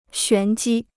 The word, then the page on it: 玄机: xuán jī